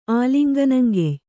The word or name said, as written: ಆಲಿಂಗನಂಗೆಯ್